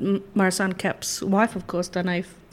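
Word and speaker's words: Danaë: Well, you did say Dane. In fact, you said Dane van der Kerk.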